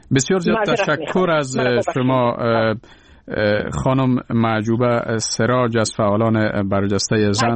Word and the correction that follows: محبوبه: معجوبه نیست، نام من محبوبه است. – محبوبه سراج، بله، معذرت میخوایم.